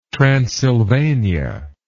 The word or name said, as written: transylvania